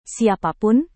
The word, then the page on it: siapapun: Indonesian